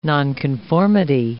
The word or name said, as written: nonconformity